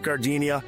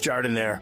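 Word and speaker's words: giardiniera: And guys, when you're at the counter, don't call it Gardenia. It's pronounced Jardinere.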